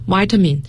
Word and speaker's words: vitamin: 那接下来呢，也是刚才给大家举例子 VC 里面的维生素，叫 Vitamin A B C， Vitamin 也可以读成 Vitamin。